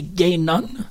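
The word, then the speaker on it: Guinan: Étrangement, la première prononciation du nom, c'est pas Guigan, c'est Gainon. Donc, il fallait qu'on dise Gainon, mais là, en même temps, je pense qu'il y a eu confusion dans l'idée qu'on voulait pas continuellement l'appeler euh, sœur homosexuelle.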